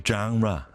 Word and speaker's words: genre: So I think I have this down, I think. A genre. Genre. I guess the point is ra.